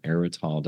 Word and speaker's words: Aerytol: Well, let's talk about the origins of Ruins and Redemption, which is book one in your Erital. Did I pronounce that correctly? Erital? – You did. Thank you. – Awesome. Thank you. It's spelled A-E-R-Y-T-O-L for all of our listeners out there.